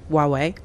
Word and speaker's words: Huawei: So the easiest way is to just imagine it's spelled with a W instead of an H at the beginning and just say Huawei. But really, you can add a little bit of a H in there as well, Huawei.